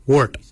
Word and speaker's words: woord: Yet is spelled Y-E-D. Yet. Yet is your chin. For throat, we say wort. For throat, we say wort. Wort is spelled W-O-O-R-D.